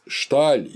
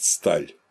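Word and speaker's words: Staal: Ну и, наконец, Фриц Шталь, или, когда его по-голландски произносят, Сталь, называл наукой ритуала.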